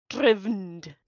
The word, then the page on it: Drivnod: Phyrexian